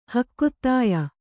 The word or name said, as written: ಹಕ್ಕೊತ್ತಾಯ